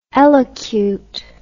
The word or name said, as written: elocute